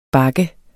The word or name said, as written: bakke